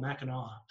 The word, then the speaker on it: Mackinac: It's spelled Island? – Mackinac Island. – Mackinac. – But somehow it's called Mackinac.